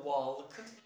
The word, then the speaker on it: walk: I don't know why, there's no rule for this, but sometimes the L is silent, okay? You have to write it, but when it comes to pronunciation, you should not pronounce it, because the L is mute, is silent, okay? So you have to say talk. It is not talk or walk.